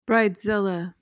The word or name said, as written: bridezilla